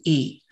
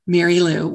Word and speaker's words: Merrylue: Yes, my name is Merrylue Martin, and Merrylue is spelled a little unusually. It is Merrylue, Merrylue, one word.